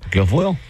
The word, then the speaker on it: Guilfoyle: If, I don't even know how you say her name, Guilfoyle? – Guilfoyle. – As a U.S. ambassador to Greece following corrupt allegations, new White House Press Secretary Katie Zakaria confirmed that Guilfoyle had been asked to resign. Guilfoyle allegedly asked a Trump donor to pay her credit card bills in exchange for administration access.